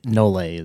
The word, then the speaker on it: nolle: Sometimes cops will say that because again we're ignorant and we don't know Latin either, but it's the nolle is how you actually pronounce it.